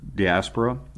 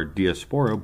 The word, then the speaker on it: diaspora: Well, and in many ways, that's why the diaspora or diaspora, both ways are actually the correct way to say it. It's like schedule and schedule. They're both right.